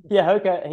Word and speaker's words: Hoka: Hoka? – Yeah, Hoka.